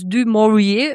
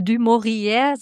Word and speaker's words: du Maurier: Es ist tatsächlich bei der Recherche mir aufgefallen, dass sie selbst, obwohl sie in London geboren wurde, ihren Namen französisch ausgesprochen hat. Da habe ich so ein bisschen überlegt, wie ich das ausspreche. Also erstmal dachte ich, okay, ich muss es Englisch aussprechen, weil sie ja aus England kommt. Mit französischen Wurzeln, deswegen habe ich am Anfang immer die ganze Zeit so dieses du Maurier im Kopf gehabt und habe jetzt aber über die Recherche rausgefunden, dass sie es selber wohl lieber französisch ausgesprochen hat.